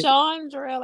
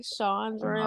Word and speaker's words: Shawndrell: It's Shawndrell, like AW, like you have to say the R, like Shawndrell.